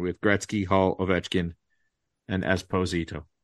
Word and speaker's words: Esposito: Esposito. That's how the Italians pronounce it.